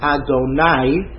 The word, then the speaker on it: Adonai: there's one time in Chumash where the letters Aleph, Dalet, Nun, Yud are pronounced Adonai with a patach under the Nun as opposed to a kamatz.